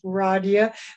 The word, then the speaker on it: Radia: My name is Radia.